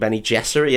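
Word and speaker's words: Bene Gesserit: That's how they pronounced it in that film, wasn't it? – Soft G. – I was taken aback. – Yeah. – That really confused. I was like, what's that word? Sounds so much like Benny Gesserit, but what could it be? – But what could it be?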